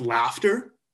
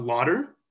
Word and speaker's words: laughter: So you spell it, but like if you replace the D with an L, now for whatever reason, it's pronounced laughter and not lauder.